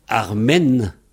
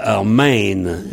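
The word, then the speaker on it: ar maen: En tout cas, les gens disent tous Armen, mais il faudrait dire Armain, évidemment. Armain. Mais bon, les gens prononcent à la française, évidemment.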